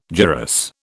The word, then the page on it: gyrus: British English